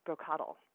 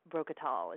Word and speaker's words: Brocottle: And since you were struggling with our little broccoli gnome guy's name, it's actually pronounced Brocottle. That's Brocottle. Although I did kind of like when you were saying Brocottal. It sounds much fancier.